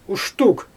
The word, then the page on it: uʃtug: Xyzyl